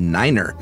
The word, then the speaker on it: niner: Most numbers are the same, with the notable exception of niner. We use niner instead of nine because over garbled radio it sounds like five, but mainly because NATO countries speak German, nine means no in German.